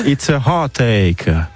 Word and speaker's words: It's a Heartache: Alors quelle est la prononciation de ce titre en anglais, évidemment? – It's a heart attack. – Presque. – It's a heartache. – C'est validé, Gorka, ou pas? – Absolument, c'est Bonnie Tyler.